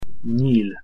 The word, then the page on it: nyil: Hungarian